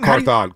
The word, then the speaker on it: Carthon: Ron Cathorn? Cathorn? – Carthon. Carthon. Carthon, thank you. I always mispronounce that.